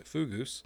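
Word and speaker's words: fogous: These kinds of structures named Fugus, pronounced Fugus, appear in Iron Age settlements in Cornwall and Scotland, but their original purpose is still unknown.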